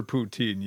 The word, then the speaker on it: poutine: People that the friends I was with were like, you're not ready for poutine yet. You can't handle it. So, yeah, poutine. You got to be tough to do poutine, man.